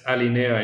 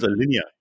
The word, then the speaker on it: Alinea: That's Don't pronounce it Alinea. Come on. – I thought it was Alinea, wasn't it? – It is Alinea. – Alinea. That's a Mexican restaurant. – I am 90% sure that this word was French at some point in its history. And it's going to be pronounced Alinea.